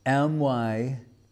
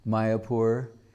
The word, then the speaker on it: Mayapur: So he's looking through these records and he sees a deed of transfer, and the area is called Myapur, Mayapur, Myapur, Mayapur, spelled like that, not M-A-Y-A, but M-Y, because English person going, my should be M-Y. Mayapur.